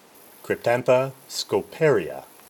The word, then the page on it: Cryptantha scoparia: Cryp-tán-tha sco-pà-ri-a